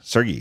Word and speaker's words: Sergey: It's like the fact that like Larry and Sergey, I think that's how you're supposed to pronounce it. I've been calling them. Pronouncing it a different way, but I got some emails. – That's a hard G. – Sergey.